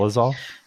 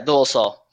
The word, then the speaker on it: Dolezal: Dolezal? – Yeah, Dolezal.